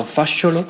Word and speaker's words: Fasciolo: That name I do pronounce right, if I'm not mistaken, because I know a little bit of Italian. So Fasciolo. And she studied film, and we will play also a little sound piece that she sent in especially for this radio program.